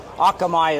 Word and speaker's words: Akamai: So yeah, a lot of people kind of have a different flavor of the name. Akamai is the proper way to say it.